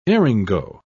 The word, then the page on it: eryngo: British English